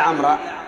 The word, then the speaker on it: عمرو: عمرو ولا عمرا؟ – عمرو. – لا. عمرو مفعول به. الواو هذه تكتب ولا ينطقبها يا. لماذا تكتب يا جماعة؟ للفرق بين عمر وعمر، ولهذا إذا كانت عمرا منصوبة ها لا تكتب الواو، لأن التنوين فرق.